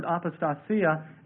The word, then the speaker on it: apostasia: And that's spelled A-P-O-S-T-A-S-I-A, apostasia, and it means the apostasy, that day shall not come except an apostasy occur.